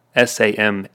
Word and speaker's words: Samhain: And Samhain is spelled like Samhain, Samhain, but it's pronounced Samhain.